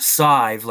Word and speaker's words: Sadhbh: It's pronounced Sive, like S-I-V-E, but it's spelled S-A-D-H-B-H.